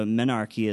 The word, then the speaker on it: menarche: That they undergo menarche is 12.5 years. I did look up that pronunciation. It is menarche.